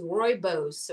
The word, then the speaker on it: rooibos: So it also is getting renamed and branded into this rooibos or robos. How do you say it? Rooibos.